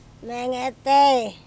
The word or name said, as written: menge tei